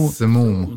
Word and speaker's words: Simum: Simum. – Simum is the way it's pronounced.